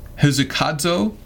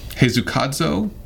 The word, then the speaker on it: hesychazo: Now, the next verb is, how do we say this one? Hezukadzo. Hezukadzo? – It's something like that. – H-E-S-Y-C-H-A-Z-O.